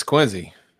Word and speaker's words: Quincy: Sean says is pronounced Quincy. Is that correct? – It is. It's Quincy. Okay, Quincy. All right, great.